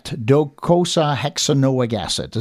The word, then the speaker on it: docosahexaenoic acid: Interesting topic today, DHA fat, docosahexaenoic acid.